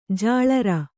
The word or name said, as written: ಜಲರಿ